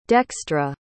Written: dextra

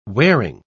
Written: wearing

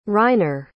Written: reiner

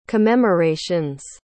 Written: commemorations

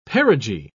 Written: perigee

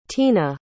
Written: teena

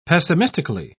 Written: pessimistically

